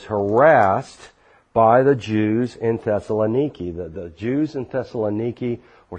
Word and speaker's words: Thessaloniki: Thessaloniki, or as American Christians mispronounce it, Thessalonica. It's Thessaloniki. We overnighted there,